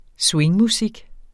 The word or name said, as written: swingmusik